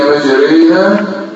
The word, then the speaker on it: مجريها: يعني تنطقها أنت هكذا مجريها، كلمة تكسر هنا وتكسر هنا. يعني الألف دي تبقى ياء والراء المفتوحة تبقى مكسورة ماشي.